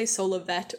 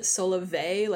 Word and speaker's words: Solovet: So I say Solovet with a T sound at the end, but I know some people pronounce it Solovet like it's French or something. But my reasoning is that if we are pronouncing dulcet with the et on the end, we should also be pronouncing et on the end of Solovet.